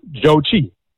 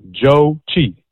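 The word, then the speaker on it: Zhou Qi: I love his American pronunciation of his name, because looking at it in print, you would not think this is how it's pronounced, but his name is pronounced Joe Chi. Simple. Joe Chi.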